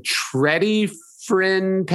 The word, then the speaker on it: Tredyffrin: I went for a run and I ended up in Tredyffrin, Pennsylvania. Am I pronouncing that right? – Yep.